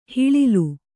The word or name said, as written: ಹಿಳಿಲು